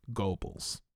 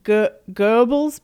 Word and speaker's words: Goebbels: Indeed, and before we get a million emails, I know that it's Goebbels, but that's that O-E German sound that we Americans can't pronounce.